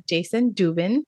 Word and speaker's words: Dubin: And today we have Jason Dubin.